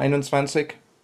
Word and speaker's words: Einundzwanzig: check my pronunciation here, Einundzwanzig meetup. – Yeah, Einundzwanzig. Yeah, it's pretty good.